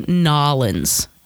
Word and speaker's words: New Orleans: New Orleans, or should I say Nolens? That's what I've been told how you're supposed to pronounce it by somebody.